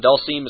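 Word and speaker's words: Dhalsim: I've heard people say, I'm going to say it's Dalsim, but some people say it's Dalsim. – Dalsim? Dalsim is correct.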